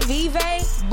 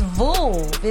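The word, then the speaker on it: Veuve: We say Vive, but when I met Vouv. – One of... It's Vouv. It's Vouv Cliqua is how you properly pronounce it.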